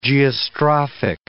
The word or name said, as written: geostrophic